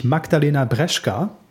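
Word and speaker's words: Magdalena Brzeska: Eine echte Olympionikin haben wir auch noch am Start, nämlich Magdalena Breschka. Ich glaube, hoffe, dass der Name zumindest so richtig ja, hast du komplett richtig ausgesprochen, ja.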